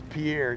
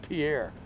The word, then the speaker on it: Pierre: It's Pierre, it's spelled like Pierre. I'm gonna pronounce it Pierre.